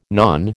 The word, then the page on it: nan: American English